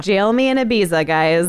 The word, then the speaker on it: Ibiza: Ibiza, is that how you say it? – Yeah.